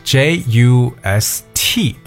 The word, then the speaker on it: just: And when 其实说到这个单词 just J U S T，对不对？其实首先在发音的时候呢，我们很多情况下是把它读成 just，嗯，just 什么什么什么，很少单独说 just，OK？、所以这个词的 T 最后一个字母呢，常常是省略掉不发音的，alright？But What is just 这个词？我相信很多人都知道，表示刚刚怎么怎么样的一层意思，对吧？